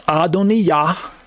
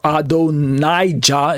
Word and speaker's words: Adonijah: The proper name, it's masculine, pronounced Adoniyah, or Adonijah is probably how you say it in English.